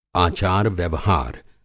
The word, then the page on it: आचार-व्यवहार: Hindi